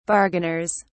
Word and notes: bargainers: British English

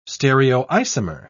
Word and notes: stereoisomer: British English

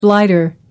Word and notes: blighter: American English

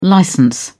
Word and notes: licence: British English

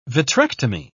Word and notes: vitrectomy: British English

